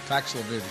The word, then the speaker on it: Paxlovid: Taking Paxil, he's got mild symptoms. – Paxlovid, excuse me.